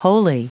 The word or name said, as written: wholly